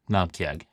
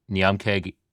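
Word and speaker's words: Naumkeag: But Namkeag is the pronunciation.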